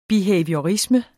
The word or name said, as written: behaviorisme